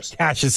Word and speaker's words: Cassius: Did I say it right? – Cassius, sorry. – Like Clay. – Sorry, my nose is plugged. Clay. Cassius Clay.